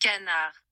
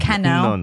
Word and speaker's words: canard: Stupid canard.